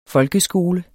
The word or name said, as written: folkeskole